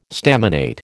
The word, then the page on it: staminate: American English